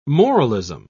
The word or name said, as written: moralism